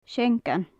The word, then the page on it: seangan: gaelic